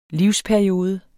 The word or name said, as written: livsperiode